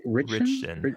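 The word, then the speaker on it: Ritchson: So like if Alan, how do you say it? – Richson.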